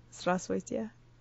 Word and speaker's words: Здравствуйте: Здравствуйте. – What does that mean? – Hello. – How do you say that again? – Здравствуйте.